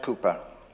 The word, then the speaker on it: Cowper: It's spelt C-O-W-P-E-R, Cowper, but it's pronounced Cooper.